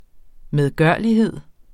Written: medgørlighed

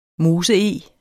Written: MOSEEG

